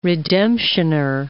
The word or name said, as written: redemptioner